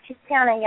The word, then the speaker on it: Tiziana: That's Tiziana.